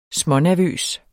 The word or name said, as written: smånervøs